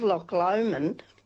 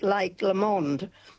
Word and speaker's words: Loch Lomond: And we had lunch with some friends and she said to me, she said, you'll love Loch Lomond. And I said, oh, are we going there? Because Tony had been pronouncing it wrong. He'd been calling it Lake Lomond.